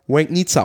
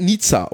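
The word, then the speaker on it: Legnica: Aber dieses L hat einen Strich. Deswegen wird es Łęgnica ausgesprochen. Und ja, nicht Łęgnica oder was hat er gesagt? Łęgnica. So ein Bullshit.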